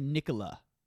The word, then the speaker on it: Nikola: Is it Nicholas Tesla? I think people say Nikola.